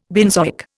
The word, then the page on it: benzoic: Indian